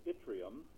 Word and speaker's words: yttrium: The development of yttrium, that's the correct pronunciation, a rare earth element, could revitalize the Ketchikan area after the loss of the pulp mill.